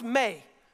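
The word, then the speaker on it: may (μή): The word that Jesus uses is spelled M-E, but it's pronounced may.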